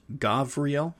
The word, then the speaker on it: Gabriel: Uh, next angel is Gabriel, pronounced Gavriel, with a V.